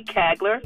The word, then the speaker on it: Kagler: Please look for Lady Kagler. My last name is spelled K-A-G-L-E-R.